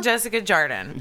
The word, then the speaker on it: Jessica Jardine: Jessica Jardine. – Jessica Jardine. – Yes, often mispronounced, reads like Jardine.